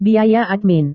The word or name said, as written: biaya admin